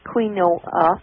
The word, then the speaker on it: quinoa: I want to tell you about quinoa. Q-U-I-N-O-A. Not quinoa, but quinoa. That's right. – What it's pronounced.